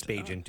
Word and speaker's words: Bagent: I heard them say, pronounce it Bagent. – It's Bagent, yeah.